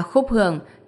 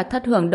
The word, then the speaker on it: Khúc Hưởng: là Khúc Hưởng chứ không phải Thất Hưởng đâu cháu ạ.